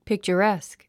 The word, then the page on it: picturesque: pìktʃərésk; ピクチャァレェスク